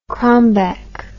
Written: crombec

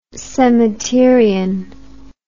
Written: cemeterian

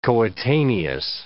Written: coetaneous

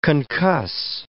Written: concuss

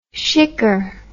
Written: schicker

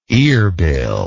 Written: erbil

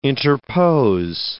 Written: interpose

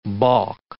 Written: balk

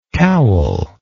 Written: Cowell

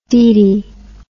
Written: didie

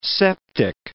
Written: septic

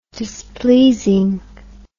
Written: displeasingness

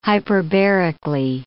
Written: hyperbarically

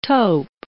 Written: tope

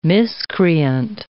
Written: miscreant

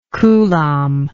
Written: coulomb

